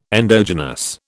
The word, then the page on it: endogenous: British English